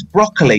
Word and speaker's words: Broccoli: Genuinely how you pronounce her surname. – I'm pretty sure it is Broccoli.